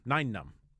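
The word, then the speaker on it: Nien Nunb: So this one was the guy who played Neenum. – Yeah. – Neenum. Ninenum. Ninenum is how you actually pronounce it.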